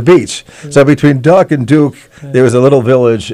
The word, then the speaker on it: Duk: So he also comes from the town of Duke. And I was asking that question. It's spelled D-U-K. And he says it's like Duke University without the E.